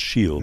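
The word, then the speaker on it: Ó Siadhail: O'Shiail, pronounced Shiel, Michal O'Shea.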